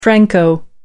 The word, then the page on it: franco: American English